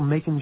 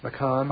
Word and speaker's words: Macon: And this is still Macon, Georgia, is that right? – Yeah, they corrected my pronunciation. – Well, what'd you say? – I don't know. – What did you say? – McCon.